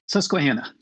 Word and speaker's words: Susquehanna: I'm going to try to pronounce this, the Susquehanna. Is that how it's pronounced? – Susquehanna.